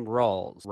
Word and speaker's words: Rawls: So my last name, Rawls, rhymes with ball.